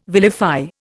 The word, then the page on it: vilify: Indian